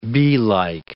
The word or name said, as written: beelike